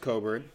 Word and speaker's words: Cockburn: And then obviously you have Ayo Dosunmu and Kofi, spelled Cockburn, pronounced Coburn.